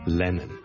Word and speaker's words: Lennon: 第一個字咧就係、是、人名嚟嘅，John Lennon。 John Lennon。 John Lennon 一個英國嘅好出名嘅歌手作曲家啦。 Lennon。 L-E-N-N-O-N。第一個係 L 音，所以唔好將佢讀成 N 音，唔係 Lennon，係 Lennon。 Len 后面嗰个先系 N 音，所以 Lennon 仲要两个 N 音添。